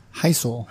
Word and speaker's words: Hisel: Today we are here with, how do you pronounce your last name? – Hisel.